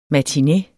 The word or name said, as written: MATINE